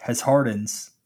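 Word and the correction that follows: Desjardins: Henry, you're talking about this Jardins. I'm one of those people, like, if I hear it, I will remember for the rest of my life. If I got to sound it out myself, it's going to be bad. But it's because it's spelled D-E-S and then, like, Jardins, like J-A-R-D-I-N-S.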